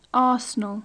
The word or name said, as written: arsenal